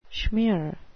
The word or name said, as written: schmeer